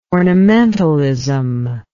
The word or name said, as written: ornamentalism